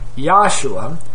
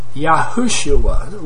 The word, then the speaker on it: Yahushua: So we must correct our error if we've been calling our Savior Yahshua. That's really incorrect. It's a lot better than Jesus, all right, a lot closer than Jesus, but his real name is Yahushua, which is four syllables. Yahshua is three syllables.